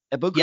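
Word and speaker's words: Egbuka: But, and this is actually where I apologize if I mispronounce this, Emeka Ebuka? Ebuka? – Yeah. Yep. Okay. Got that one right there.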